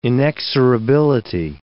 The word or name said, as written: inexorability